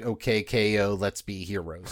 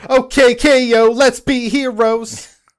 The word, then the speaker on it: OKKO Let's Be Heroes: But also, I didn't direct OKKO Let's Be Heroes, so what do I know? – Wait, hold on. You didn't say that right. – OKKO Let's Be Heroes! – There we go.